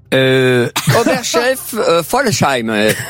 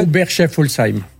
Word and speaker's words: Oberschaeffolsheim: Oberchef Folsheim. – Comment ça se prononce, en fait? – Oberchef Folsheim. – Mais attendez, O-B-E-R-S-C-H-A-E-F-F-O-L-S-H-E-I-M.